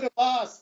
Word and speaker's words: Abbas: But it says Mahmoud Abbas, thank you. It rhymes with Hamas.